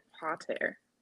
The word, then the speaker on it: Pater: So I listened to the audiobook and they pronounce it Pater. – Pater. Oh, yes. I read it physically. – I was like, is this like a fancy word for Potter? But it sounds like Pater.